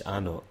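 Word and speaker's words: anCnoc: And it has, how you say it, pronounced A Knock. Straight underneath the name of the whisky. – Yeah, it's Anok.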